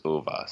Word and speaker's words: Ove: Well, regardless, we then listened to the author himself talk about this book, and he said it is Ove. So apparently the Swedish pronunciation.